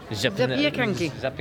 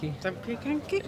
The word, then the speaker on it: Zapiekanki: Zapiekanki. – Zapiekanki.